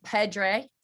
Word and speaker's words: Pedre: I'm very honored today to share an incredible guest who's been on all over television, all over social media, Dr. Vincent Pedre.